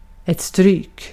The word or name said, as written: stryk